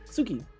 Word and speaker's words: Tsuki: T-S-U-K-I. Tsuki? Suki. – Suki. T-S-U-K-I. Suki, yeah. It's just Suki.